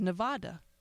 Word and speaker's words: Nevada: they have no idea that it's pronounced Nevada. Because everyone I know on the East Coast says Nevada.